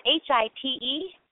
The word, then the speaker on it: Hite: And the last name is spelled H-I-T-E, and I'm only 5'4", so don't hold it against me. You know, height. I don't match the last name, but...